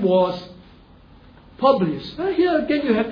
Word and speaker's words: Publius: Publius.